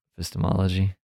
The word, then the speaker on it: epistemology: Uh, I'm sorry, how did I say it? you emphasized piss. – Epistemology.